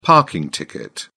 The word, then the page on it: parking ticket: British English